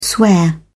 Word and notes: swear: British English

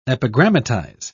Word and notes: epigrammatize: British English

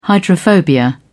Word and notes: hydrophobia: British English

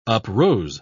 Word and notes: uprose: British English

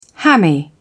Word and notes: hammy: British English

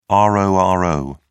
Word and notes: roro: British English

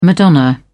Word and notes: madonna: British English

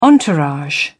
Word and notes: entourage: British English

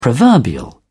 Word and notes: proverbial: British English

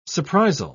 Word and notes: surprisal: British English

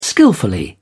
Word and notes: skilfully: British English

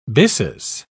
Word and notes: byssus: British English

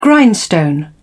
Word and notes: grindstone: British English

